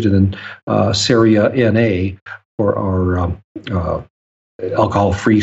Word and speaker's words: Ceria: Yeah, C-E-R-I-A. Yeah. And pronounced, it rhymes with area, Saria Brewing Company.